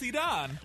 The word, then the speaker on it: Iran: Do you like my pronunciation now? – Yes. – He criticized me all day yesterday. – So glad Iran, it's Iran.